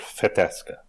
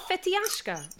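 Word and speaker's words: Fetească: Fetele, and the variety is called Fetesca. Fetesca regala, yeah. – Oh, it's not Fetiashka. – No.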